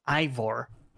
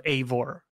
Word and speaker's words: Eivor: Also, by the way, just from Silver to leave his footprint in this, even though he couldn't make it today, but he said the name is pronounced Ivor instead of Eivor.